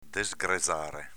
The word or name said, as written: desgrezare